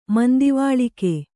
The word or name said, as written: ಮಂದಿವಾಳಿಕೆ